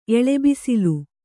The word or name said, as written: ಎಳೆಬಿಸಿಲು